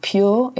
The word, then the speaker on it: Pjur: There's a really good one that I like called Pure. It's spelled P-J-U-R.